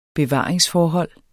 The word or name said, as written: bevaring